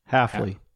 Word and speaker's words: Hafley: Just in case, because Half does play. Did I say his name right? – Hafley. – Hafley.